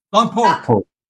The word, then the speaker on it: Lompoc: Lompoc.